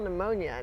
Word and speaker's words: pneumonia: M-E-2. – My dad used to say, like, someone, like, how do you spell pneumonia? And he would spell it out, and he would say, the P is silent, as in swimming.